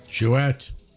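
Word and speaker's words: Jouët: Jouet. Moët Chandon Perrier Jouet. You've got an umlaut over the E, you pronounce the T.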